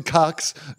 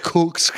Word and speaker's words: cuck: But confusingly, just turn to bull, but to dress as a We call them cuckoos, even though it's spelled cuckoo, but we call them cucks, not cooks, because that could actually get really confusing.